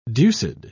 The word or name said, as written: deuced